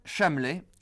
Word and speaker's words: Chamelet: Un petit chameau, c'est un chamelet. Eh bien, chamelet, le village du Beaujolais où nous sommes ce matin, s'écrit de la même façon, C-H-A-M-E-L-E-T.